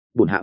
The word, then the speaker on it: bổn hạ: Yến ngụy biện tạm như vậy, cái từ bổn hạ trước hắn cũng dùng, nhưng học chữ hắn nhiều nên thiên đọc là bản hơn bổn hữu. Thú vị ngươi tên chi? Bản hạ à không, bổn hạ Hoàng Ngọc Yến.